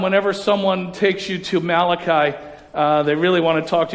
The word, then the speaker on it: Malachi: Some people like to pronounce it Malachi, but it's Malachi. Malachi.